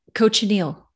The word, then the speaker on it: cochineal: Cochineal.